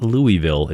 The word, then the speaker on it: Louisville: That's right, Louisville. Not Louisville, not Louisville, it's Louisville. Two syllables.